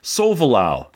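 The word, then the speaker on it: Solvalou: The name of your ship in Xevious, which is also the name of the Xevious sequel, is Sovalau. – Oh, so the OU is pronounced like an English OU? – I guess, Sovalau.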